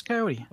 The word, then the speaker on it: Coyote: Coyote. How would you pronounce that? – No. Yeah, that's Coyote.